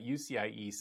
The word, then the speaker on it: UCIe: It rhymes with CXL. You want to talk about that for a moment? – On UCIe, I am I'm not currently a member of the board.